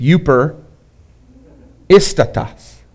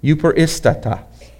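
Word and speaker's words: Uper Istatas (εὐπερίστατος): Because it's Uper Istatas is how that word is pronounced. Uper Istatas.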